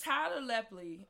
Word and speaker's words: Tyler Lepley: Tyler Lepley. I think that's how you say his name. Yes.